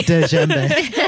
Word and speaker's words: djembe: Is it pronounced djembe? – That's how I've heard it, but it's duh, djembe.